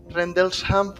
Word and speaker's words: Rendlesham: Rendelsham Forest, a ver si lo digo bien, Rendel, Rendlesham Forest.